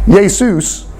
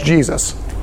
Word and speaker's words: Jesus: So that's, in English, it becomes Jesus. So that's how, it's only in the last 400 years that it's got the hard J on it.